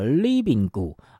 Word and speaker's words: Libingu: 非常活泼轻快的这首客语歌 RIBINGU、啊、是他们的团名，要怎么念呢、啊、叫 Libingu、啊、这个重音、啊欸、